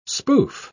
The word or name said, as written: spoof